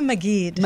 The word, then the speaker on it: Maggid: Randi Maggid.